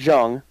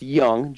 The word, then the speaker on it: Jung: Who is a Josh Jung, not Jung, it's pronounced Young, Josh Jung for Ranger fans out there. I know it's spelled J-U-N-G.